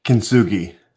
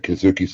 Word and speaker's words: Kintsugi: Kintsugi. – Yeah, I said that. Kintsugi cigar.